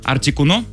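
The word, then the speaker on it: Articuno: проверил ударение, вроде так. Артикуно, Заптос и Молтрес.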